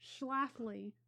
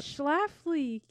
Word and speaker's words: Schlafly: this? Scuffly? – Schlafly. – It's late at night, people. Anyways, continue. Yeah, go for it. Uh, write it in comments down below. How do I pronounce that. Schlafly.